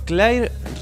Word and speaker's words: Claire: Claire Regan, que se escribe C-L-A-I-R-E, Claire.